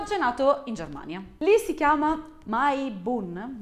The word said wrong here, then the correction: Maibaum: Maibaum. Ah già, Baum. Si chiama Maibaum.